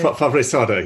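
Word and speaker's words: Favret-Saada: but I think it's like Jean Favretsada. Is that how you say it? – Favretsada, yes.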